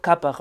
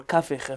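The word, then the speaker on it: Kafih: And this new series is in the Rambam's Mishneh Torah, the first book, using the edition of Rabbi Yosef Kapach, or Kafich, if you want to pronounce his name properly. I'm using the name Kapach, and we'll be using that name throughout the Kollel, because essentially that's how he's referred to in the modern Israeli street.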